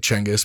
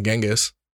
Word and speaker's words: Genghis: Yeah, I said Genghis because that's how it's supposed to be pronounced, not Genghis.